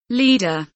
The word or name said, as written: leader